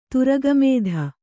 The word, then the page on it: ತುರಗಮೇಧ: turaga mēdha